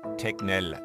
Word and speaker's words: Tegnell: Anders Tegnell ausgesprochen. Also ein härteres G und dann ein Tegnell und Anders der Vorname.